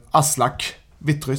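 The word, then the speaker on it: Aslak Witry: Eh, sedan eh, så eh, såklart att har nu Aslak Vittry, säger man det? Uttalar man det mm. rätt? – Ja.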